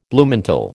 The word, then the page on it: Bluemantle: American English